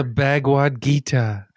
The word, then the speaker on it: Bhagavad Gita: The Bhagavad Gita.